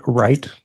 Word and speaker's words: Wright: Yes, Rick Wright, W-R-I-G-H-T.